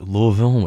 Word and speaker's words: Louisville: That's Louisville with two syllables, if you're pronouncing it correctly.